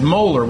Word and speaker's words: molar: You pronounce it as molar when you see the capital M.